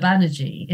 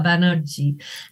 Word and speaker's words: Banerjee: I'm, you know, my name is pronounced in Bengali. I'm in English, I'm Sheela Banerjee. In Bengali, I was Sheela Banerjee.